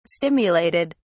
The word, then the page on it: stimulated: British English